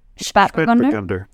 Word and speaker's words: Spätburgunder: Spätburgunder.